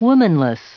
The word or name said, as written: womanless